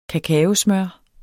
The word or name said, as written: kakaosmør